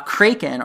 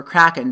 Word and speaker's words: kraken: Uh, kraken or kraken, depending on how you want to pronounce it, is like a squid thing and a cape, and it destroys the water crystal.